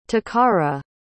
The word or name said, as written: toccara